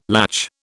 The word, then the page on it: latch: British English